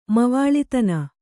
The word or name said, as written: ಮವಾಳಿತನ